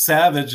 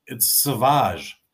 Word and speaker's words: Sauvage: Everyone says it's Savage, and it's Sauvage.